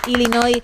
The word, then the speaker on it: Illinois: Así que Illinois y la S. Así que sin pronunciar la S final, Illinois, una vez más, tu apunte de geografía que nos viene muy bien, porque si no lo tuviésemos ya nos quedaríamos raros.